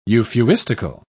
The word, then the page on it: euphuistical: British English